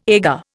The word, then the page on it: agar: Indian